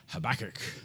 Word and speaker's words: Habakkuk: Habakkuk. You can pronounce that Habakkuk.